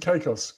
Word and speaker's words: Caicos: Apparently, Gator also swam over to Turks and Siakos. – Oh, nice, Caicos. Gator. – I don't know how to pronounce that. Is that Caicos? Caicos. I don't know how to pronounce it. – Turks and Caicos.